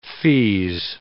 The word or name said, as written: feeze